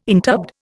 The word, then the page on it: interbed: Indian